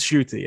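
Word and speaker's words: Ncuti: But Shooty Gatwa from Sex Education. – Is his name Shooty? – Like, yes. – Okay. Like, pronounce Shooty. – Pronounce Shooty, yes.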